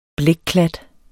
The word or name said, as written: blækklat